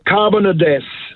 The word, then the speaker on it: Carbonados: I think I'm trying to pronounce it right. Carbonados. It is C-A-R-B-O-N-A-D-O-S. Carbonados.